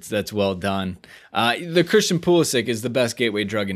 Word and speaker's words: Pulisic: Pulisic is the correct pronunciation. Pulisic. I want to say that right. – Yes. – Pulisic.